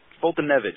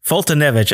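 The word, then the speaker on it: Foltynewicz: Just do it phonetically. It's easy. No, it's Fultonevich. – Fultonevich.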